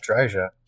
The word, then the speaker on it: Dryja: I can't say Tadge Jaija. How would you say that? – Yeah, Tadge Dreja, Tadge Dreja, Dryja. Tad That's a beast of a last name.